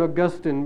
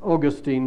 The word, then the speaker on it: Augustine: I call him Augustine because that's the British pronunciation, and I'm British. I believe that some of you say Augustine here, and you'll forgive me if I stick to my own pronunciation.